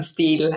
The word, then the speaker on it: Steil: Also ich bin halb, halbe Luxemburgerin, halbe Französin, aber Steil wird halt so, so ausgesprochen, wie du es ausgesprochen hast. Aber für die, die äh, Zuschauer, die mich von meinen Streams oder so kennen, würde mich wahrscheinlich sehen. Äh, auf Englisch sage ich dann Stil, aber hier passt das schon sehr gut so.